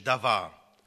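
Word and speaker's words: Davar (דבר): Das Thema heißt: Der biblisch-semitische Aspekt in der Grundstruktur der Worttat Gottes, auf Hebräisch Davar. Das B mit dem Unterstrich wird wie V ausgesprochen.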